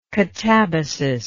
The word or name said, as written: catabatic